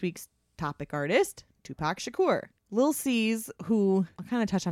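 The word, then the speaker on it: Lil' Cease: And I say Lil C's, it's spelled Lil Cease, but it's short for Little Caesar.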